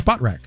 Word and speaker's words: Spotrac: I never know what it's, is that what it's, how it's supposed to be pronounced? Do you know? – Well, how do you pronounce it? – I say SpotRac, but I have no idea. – It's SpotRac. – Is it really? – Yeah.